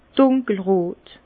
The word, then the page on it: dùnkel rot: Bas Rhin; Herrlisheim